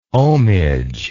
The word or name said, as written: ohmage